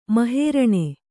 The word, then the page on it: ಮಹೇರಣೆ: mahēraṇe